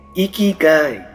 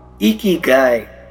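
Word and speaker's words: इकीगाई (生きがい): First you have to learn to pronounce it. You have to lift your cheeks as if you are smiling and you have to से इकीगाई। से with me, come on, इकीगाई।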